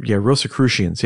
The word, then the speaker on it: Rosicrucians: Rosicrucians? – Rosicrucians, yeah.